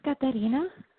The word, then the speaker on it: Caterina: Hi, my name is Katarina. – Could you spell that, please? – Hi, Katarina. Sure, sure. C-A-T-E-R-I-N-A.